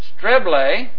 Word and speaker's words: streble: So, hey, by the way, you see at the end of this, a streble, that's S-T-R-E-B-L-E with a long E, that would be an eta in the Greek, was a winch, an instrument of torture.